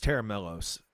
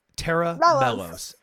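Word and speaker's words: Tera Melos: So, well, the way you pronounce it is Terra Milos. Terra Milos.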